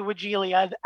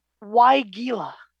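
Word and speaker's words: Weigela: I say Wigilia. Add that extra vowel in there between the L and the A. Yes. But if you look it up in the pronunciation guides for horticulture, it's Wigilia.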